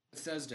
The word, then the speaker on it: Bethesda: Bethesda.